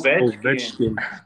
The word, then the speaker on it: Ovechkin: So like I grew up watching Alex Ovechkin. I can't say his name. Alex Ovechkin.